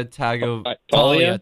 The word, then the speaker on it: Tagovailoa: Tulia Tagovailoa.